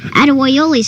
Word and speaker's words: Adewoyoli: Adewoyoli is how you say it in Cherokee.